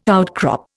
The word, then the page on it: outcrop: Indian